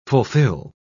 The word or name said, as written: fulfill